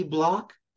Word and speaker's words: Block: Block, and that's spelled BLOCK.